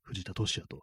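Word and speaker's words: 藤田敏八: はい、藤田俊あの、ビンパチと書いてね、都市にね、 8 と書いてね、藤田市也と読むんですけども、なん、なんて映画、名前が、あ、そうだ、あの、帰らざる日々っていう、ね、映画ですね。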